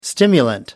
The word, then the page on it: stimulant: American English